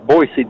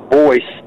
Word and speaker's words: Boise City: It's not pronounced Boise, though. We just call it Boyce, but okay.